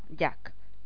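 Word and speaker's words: Jacques: Jax y Gabriela debutan. Jax no, Jack. Pronunciemos bien. Y no un francés de Vallecas. – Pues no, le llámalo Jack.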